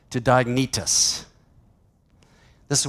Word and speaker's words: Diognetus: It's a long E, Diognetus.